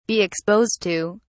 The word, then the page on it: be exposed to: British English